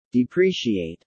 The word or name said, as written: depreciate